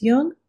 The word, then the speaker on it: Jung: Just be sure to pronounce Jung with a hard J. And you've been listening to a very special quarantine edition of Speaking of Jung.